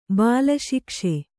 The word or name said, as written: ಬಾಲಶಿಕ್ಷೆ